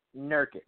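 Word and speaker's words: Nurkić: I can never pronounce Nurkic. – His name. Jokic? – Yusuf Nurkic. – Nurkic.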